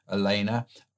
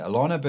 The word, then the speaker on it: Alana: Jared and his beautiful wife, Elena, I would pronounce it Alana, but he pronounces it Elena, so I want to get that right.